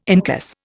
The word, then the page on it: incus: Indian